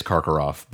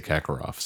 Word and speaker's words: Karkaroff: It is Karkaroff, but, you know, it's hard for me because in the movies they say Kakarov. So it is Karkaroff. He sees Karkaroff, and they probably even say it right in the movies, but they say it so quickly you can't hear that R, I suppose, and realizes that he's probably there doing some scouting so he can give Victor Krum some early information, right? – Yeah, some hints about the first task.